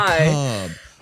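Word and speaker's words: Mechabre: It has been confirmed that it is macabre, but it's spelled like mech and then the back half of the actual word macabre.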